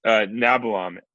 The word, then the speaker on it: Nabalom: Nabilon. N-A-B-A-L-O-M.